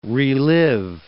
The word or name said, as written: relive